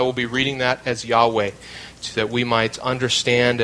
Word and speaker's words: Yahweh: it is to render the Hebrew tetragrammaton that is best pronounced as Yahweh.